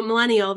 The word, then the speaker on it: Millennial: so Millennial, the hashtag is silent, by the way, um, is actually hosted by myself, Andrew, Matt, and Elisa.